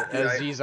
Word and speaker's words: Aziz: The whole rest of that cast is so talented that it's like, well, except for Aziz Ansari, because, you know, we do not like him. Isn't that his name, right? Am I butchering his name? – Aziz, yeah.